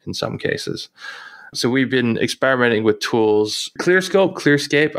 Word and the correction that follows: Clearscope: I always forget how to pronounce it. – ClearScope last year.